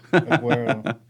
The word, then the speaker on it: Aguero: No. That sounds like Aguero. Aguero.